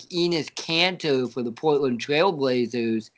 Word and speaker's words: Enes: Enes. – It rhymes with penis.